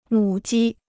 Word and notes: 母鸡: mǔ jī

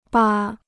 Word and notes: 扒: bā

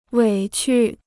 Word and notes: 委屈: wěi qu

